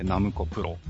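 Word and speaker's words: 765プロ: そうです、そうです、うん、で、で、そこの舞台というか、うん、えー、出てくる子たちが所属しているのが、まあ、 765 と書いてナムコプロ。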